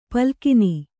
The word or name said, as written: ಪಾಲಘ್ನ